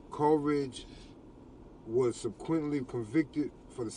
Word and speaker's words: Coleridge: Coleridge.